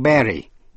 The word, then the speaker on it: Bury: Âm U đọc là E trong Berry, B-U-R-Y, Berry là chôn, Burial Ground là nghĩa trang.